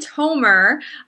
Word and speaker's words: Tomer: And hopefully I was pronouncing your last name correctly. I don't know if I ever asked you about that. But yeah, it's actually Tomer.